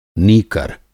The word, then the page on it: नीकर: Hindi